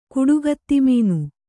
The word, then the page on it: ಕುಡುಗತ್ತಿಮೀನು: kuḍugatti mīnu